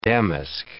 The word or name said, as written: damask